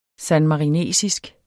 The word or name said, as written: sanmarinesisk